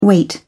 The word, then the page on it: wait: British English